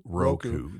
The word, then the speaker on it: Roku: I think it's a long O. Roku.